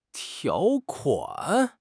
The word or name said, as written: 条款